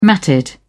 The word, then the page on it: matted: British English